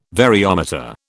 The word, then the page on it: variometer: British English